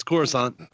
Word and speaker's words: Coruscant: See, in the movies, it's Coruscant. – Yeah. – They do say it like that? – Yeah.